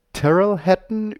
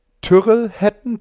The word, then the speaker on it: Tyrrell Hatton: Und ähm, die ranken von Terrell Hatton über Tyrrell Hatton.